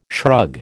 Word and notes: shrug: American English

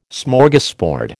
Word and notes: Smorgasbord: American English

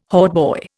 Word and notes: hautboy: Indian

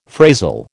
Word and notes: phrasal: American English

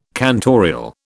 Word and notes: cantorial: British English

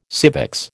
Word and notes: civics: American English

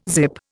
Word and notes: zip: Indian

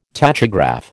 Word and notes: tachograph: American English

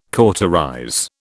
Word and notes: cauterize: British English

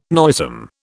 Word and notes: noisome: British English

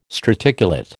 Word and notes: straticulate: American English

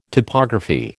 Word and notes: topography: American English

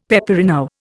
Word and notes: peperino: Indian

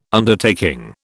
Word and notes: Undertaking: British English

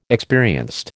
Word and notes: experienced: American English

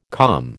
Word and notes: COM: American English

